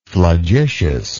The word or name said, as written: flagitiousness